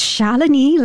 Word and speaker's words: Shalini: So it's Shalini, like felony, you know, not Shalini.